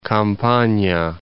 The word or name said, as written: Campania